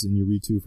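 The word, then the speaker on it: Iñárritu: Alejandro Gonzalez in Uritu for The Revenant. – Nice way to pronounce that.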